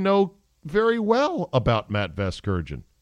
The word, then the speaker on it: Vasgersian: Vergasian, is that how you say it? – No, you're not saying it right.